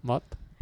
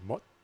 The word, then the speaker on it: Moth: Se llama Mot. Mot. Eso es. – Mot. – M-O-T-H. Eso es. Vamos con ella. Ellos suenan así. Today. El tema que hemos puesto se llama Retaliation, es del grupo Mott. Mott.